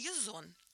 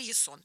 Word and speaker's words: Ясон: Их предводителем был царевич Язон, или Ясон, да, по-разному можно произносить его имя.